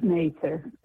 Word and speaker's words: Mather: And it's Mather. That's the way to pronounce Mather.